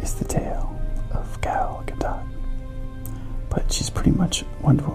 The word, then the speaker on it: Gadot: As many of the fans are familiar, Gal Gadot, and yes, the T is pronounced, is an Israeli.